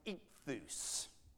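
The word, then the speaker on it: ichthus (ἰχθύς): Well, behind me, you find the Greek word for fish, which is pronounced ichthus, all right?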